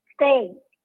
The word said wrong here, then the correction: Tate: ¿Cómo se escribe? Eh, T. – Tate. – Ajá.